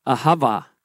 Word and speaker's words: ahava: That word love means avaha, or actually that's how you pronounce it, ahava.